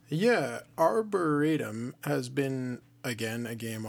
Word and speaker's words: Arboretum: So the second game new to the collection is Arboretum. I think that's how you say it. I heard Norm talking about it, and that's how he says it. So Arboretum.